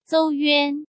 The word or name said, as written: Zou Yuan